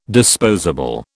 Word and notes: disposable: British English

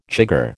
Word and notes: chigger: American English

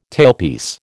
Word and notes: tailpiece: American English

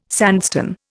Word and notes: sandstone: Indian

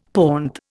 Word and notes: pont: Indian

